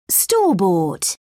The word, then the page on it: store-bought: British English